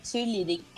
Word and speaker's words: cheerleading: Se você é quiser falar com sotaque americano, é cheerleading.